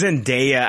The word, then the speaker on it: Zendaya: Is that how you pronounce it? Zendaya? – It's Zendaya. I had a listener piss and moan about it once. A couple times. I had a fucking listener stop listening to our show. We had a listener, Jake, that stopped listening to our show because of the way I pronounce Zendaya. – Some of the fucking dumbest shit I've ever fucking heard. – No shit. All right, I think it's Zendaya.